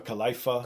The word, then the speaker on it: Khalifa: I couldn't pronounce his name at first. I was like, what kind of Wiz? What is that? – Was Khalifa, you know? Khalifa. – Khalifa. Now I know it.